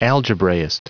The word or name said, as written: algebraist